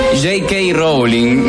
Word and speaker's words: J.K. Rowling: Terrible, ni J.K. y Rowe, no lo sabía ni pronunciar, ni... Bruto, Dios mío. Ay, que siempre me olvido. J.K. Rowling, ni J.K. Rowling, escribió esto, loco.